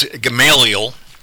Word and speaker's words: Gamaliel: But the Gamaliel name, and congratulations on pronouncing it correctly. Some people, when they see it, pronounce it Gamaliel or something words. – Like that. It's you're right, it's Gamaliel. But in the book of Acts,